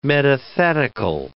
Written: metathetical

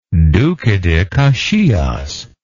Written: Duque de Caxias

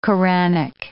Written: Koranic

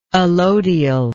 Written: allodially

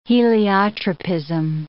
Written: heliotropism

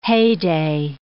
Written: heyday

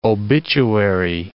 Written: obituary

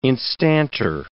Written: instanter

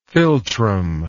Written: philtrum

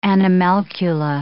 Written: animalcula

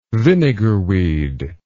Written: vinegarweed

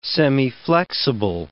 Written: semiflexible

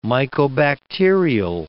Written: mycobacterial

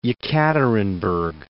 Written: Yekaterinburg

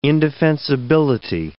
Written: indefensibility

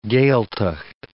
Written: Gaeltacht

